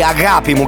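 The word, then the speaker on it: agapimu (αγάπη μου): Vi segnalo che agape è una parola greca che eh, fa parte di una parola più complessa che si, eh, si pronuncia così agapimu, che vuol dire amore mio.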